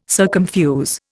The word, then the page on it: circumfuse: Indian